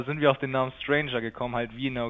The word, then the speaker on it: strainjr: Ich unterhalte mich jetzt mit der Band Stranger, aber die schreibt man nicht so wie das englische Wort Stranger, also so etwas wie komischer oder verrückter, sondern wie Strain, das englische Wort für Belastung und JR für Junior.